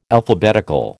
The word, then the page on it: Alphabetical: American English